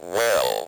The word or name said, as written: well